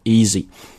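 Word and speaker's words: Yeezy: Easy，它的发音呢其实跟我们讲那个容易那个单词是一模一样的，咱们中国的同学呢就把它翻成了叫椰子鞋啊，而且呢大家如果稍加关注这个所谓的叫明星联名款啊，这种潮人鞋的话，才知道这个鞋其实一点都不便宜啊。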